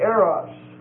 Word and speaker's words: eros: And the last word that we'll look at is the word eros, E-R-O-S, and it's a long O.